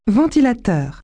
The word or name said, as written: fan